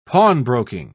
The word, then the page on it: pawnbroking: British English